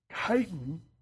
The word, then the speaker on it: chitin: And the cell walls are made of a material called chitin.